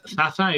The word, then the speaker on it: Zazza: De Sasa, ¿no? – El de Sasa, el italiano, que si no lo conocéis, es Zaza, es Z-A-Z-Z-A.